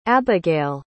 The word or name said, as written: abegayle